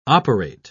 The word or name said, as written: operate